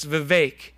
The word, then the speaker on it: Vivek: He says it's Vivek. It rhymes with cake.